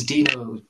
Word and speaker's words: Deno: I think it's spelled DENO. It's meant to be Node, but with the letters moved around because oh, okay.